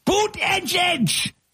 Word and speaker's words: Buttigieg: And you had Buttigieg, Pete Buttigieg, Buttigieg! Mayor Pete, boot, it's pronounced boot, edge,